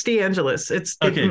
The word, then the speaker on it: DeAngelis: It's DeAngelis.